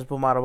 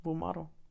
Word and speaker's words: Bulmaro: Bulmaro.